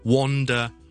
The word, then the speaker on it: wander: 第一个字是 W-O-N-D-E-R, wonder, wonder 就是一些很奇妙的东西，还有 W-A-N-D-E-R 是读 wonder, wonder, 周围游魂野鬼的游魂呢就叫做 wonder, 周围游、 okay? 周围碌周围行就 wonder。咁、那个发音系有啲奇怪嘅，因为虽然我串字 W A N，但系我系读 o o o 音嘅，所以 wander around the neighborhood，在这个附近的地方周围游叫 wander。而 w-o-n-d-e-r 呢，虽然它是 o，但是我不是读 o 音的，我是读 a 这样的音，所以 one, one, one, one, two, three 个 one 就得噶啦。